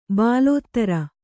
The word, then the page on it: ಬಾಲೋತ್ತರ: bālōttara